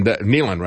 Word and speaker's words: Nehlen: And Nehlen, rather, is the way you pronounce his name.